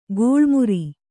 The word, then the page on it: ಗೋಳ್ಮುರಿ: gōḷmuri; Kannada